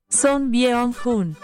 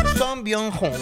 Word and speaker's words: Son Byung-hun: Este es el presente de Son Bion. ¡Ah! Se quiere decir, bueno, es que yo no he ido allá. ¿Cómo lo pronuncia usted? Por favor. – Son Byung-hun. – Ay, Son Byung-hun.